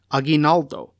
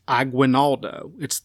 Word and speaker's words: Aguinaldo: And in particular, I will refer to the Philippine Republic's president with his Spanish pronunciation, Aguinaldo, and not the English version, which is pronounced Aguinaldo. That's not the accurate pronunciation.